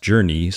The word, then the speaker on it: Journey: Now, I personally own a Bowflex T10 that features its own proprietary JRNY, I believe it's supposed to be pronounced Journey, software. And while I totally dig the dedicated run workouts that come packaged with the Journey software, I especially like putting on travel destinations where I've actually been on the screen while I run.